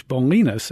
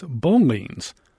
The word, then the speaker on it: Bolinas: These busy newcomers soon discarded Rancho from the area's name, and they mispronounced the Spanish Bolinas as Bolines. Some people took that as a corruption of the word baleen, which is a type of whale.